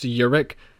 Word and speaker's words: Jurek: It's it's actually spelled uh, J U R E K and it's pronounced Yurik.